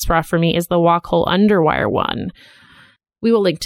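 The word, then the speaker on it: Wacoal: Is that how you say it? – That's how I've always said it, Wacol.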